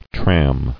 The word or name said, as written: Tramming